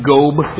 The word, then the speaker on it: gob: By the way, if you do look it up in Strong's, it does say that the word translated is like gob, is how you say it.